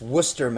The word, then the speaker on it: Worcester: Rob, from, I think it's pronounced Worcester, Massachusetts. – Worcester, yeah. – Worcester. Even though it is, there are many letters in there that are not pronounced.